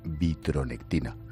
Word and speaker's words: vitronectina: gracias a la vitronectina.